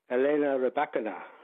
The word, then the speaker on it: Elena Rybakina: Well, I hope I get the pronunciation right. It's something like Elena Rybakina. – Yep, sounds pretty good to me.